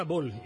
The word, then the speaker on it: ball: Atención a la palabra bol y a sus expresiones derivadas. Bol, este anglicismo debe escribirse entrecomillado o en cursiva, se escribe ya saben B-A-L-L.